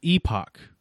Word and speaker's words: Epoch: Yeah, I was saying Epic, but I knew that it wasn't spelled like Epic. So right. I was like holding out the O, but it's Last Epoch apparently.